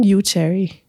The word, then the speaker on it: Yuchari: Yuchari.